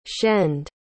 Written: shend

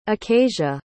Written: acasia

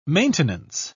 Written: maintenance

